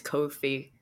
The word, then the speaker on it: Ko-fi: It rhymes with no-fi, so it's Ko-fi.